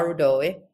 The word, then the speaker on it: Rudoy: So my name is Ayala Rudoy. This is the English pronunciation version. So you did just fine.